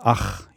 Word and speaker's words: ach: And then the H has a roof, which makes it to this for English natives quite hard sound like ch, like in German Rachen, yes, yeah. – Ach, yes.